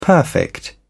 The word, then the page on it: perfect: British English